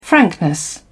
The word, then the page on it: frankness: British English